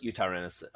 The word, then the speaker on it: Eutyrannus: For example, mine would be Eutyrannus. Is that the right saying, yeah? – Eutyrannus.